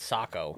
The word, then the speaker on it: Saco: In 1999, the folks of Saco. I don't know how to pronounce it in fucking Maine either, so Montana, you're on your own. – Maine, it's Saco.